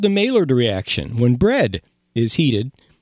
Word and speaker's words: Maillard: Maillard.